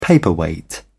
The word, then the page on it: paperweight: British English